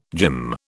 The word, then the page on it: gym: British English